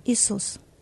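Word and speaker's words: Isus (Ісус): Isus. – Isus. – Isus. – Isus. Amen. – Isus. – What a name. Amen. Yeah. What a name. Isus. And he is Isus.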